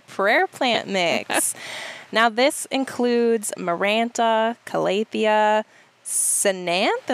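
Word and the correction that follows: Ctenanthe: I say it Tananthi. – Tananthi. – So the C is silent. C-T.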